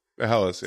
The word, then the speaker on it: Helles: I had a hell, is it okay, so how do I pronounce it? Is it Helles Lager? – Helles, yeah.